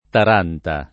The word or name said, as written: Taranta